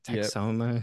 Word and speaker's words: Texoma: Um, and then Texoma, is that how you pronounce that? – Texoma.